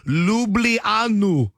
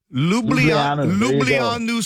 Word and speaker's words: Ljubljana: This is a 5,000-point Scrabble word pronounced, and I had to look this up. I would have never gotten this. Ljubljana. Ljubljana, Slovenia.